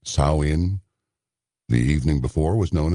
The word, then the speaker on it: Samhain: It is thought to have originated with the ancient Celtic festival of Samhain. I was going to say Shamhain, because it's spelled S-A-M-H-A-I-N.